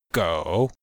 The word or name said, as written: gou3